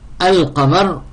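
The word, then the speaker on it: Al-Qamar (القمر): Alors Al-Qamar, on ne peut pas sauter le lem. On ne peut pas dire Ak. Ça, pas, donc on ne peut pas le prononcer. Donc on dit Al-Qamar.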